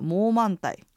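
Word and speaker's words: 冇問題: はい、であのもう満体で多分皆さんちょっと聞いたことがあるかもしれないんですけれども、はい、あの映画で有名になった「モーマンタイ」はい、というのがありますが、これは同じ感じであの香港だとか、まあ、あの広東省の方で使われる、うん、あの広東語の発音ではメイウェンティはモーマンタイと発音します。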